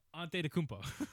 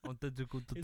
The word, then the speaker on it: Antetokounmpo: Did you say that right? – Yes. Antetokounmpo. – Antetokounmpo.